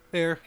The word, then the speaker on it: Ayr: And Ayr? How do you say that? – Ayr Canada. Yeah. – That's what I just said. Is that how you say Ayr? – Ayr. Ayr. Ayr. – Air. – Air.